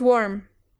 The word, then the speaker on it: warm: The word is warm. – That's right. Warm. Con una M al final. Warm. Boca cerrada, labios cerrados, etc.